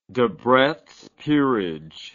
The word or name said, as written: debretts peerage